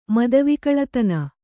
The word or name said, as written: ಮತಿವಿಕಳತನ